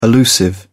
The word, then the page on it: allusive: British English